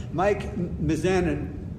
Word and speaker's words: Mizanin: We have Mike Mizanin.